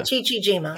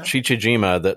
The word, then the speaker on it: Chichijima: Chichijima. – Chichijima, the Japanese island that then Navy pilot George Bush was flying over, was attacking when he was shot down in 1944.